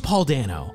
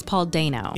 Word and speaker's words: Paul Dano: Paul, I thought his name was Paul Dano. – Yes, but it's Paul Dano.